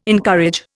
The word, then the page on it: encourage: Indian